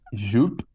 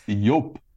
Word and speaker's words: Yope: So it's not Jupe, it's Yope.